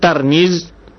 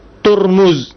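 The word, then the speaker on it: Tirmiz (ترمذ): Tirmiz itu berbeda-beda cara baca. Ada yang baca Tirmiz dengan baris di bawah tanya, ada yang baris di atas Tirmiz, Tirmizi, Tirmizi atau Turmuz. Tapi yang masyhur dibaca adalah Tirmiz.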